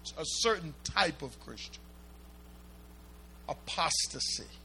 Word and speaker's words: apostasy: You pronounce that apostasy. Apostasy.